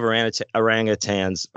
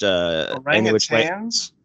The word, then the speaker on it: orangutans: Speaking of orangutans, we watched orangutans. – Yeah, that's how you say it. Orangutans. Yeah. We watched Any Which Way. – I always said orangutans. – Yeah. – I guess I'm wrong.